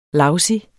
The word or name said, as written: lousy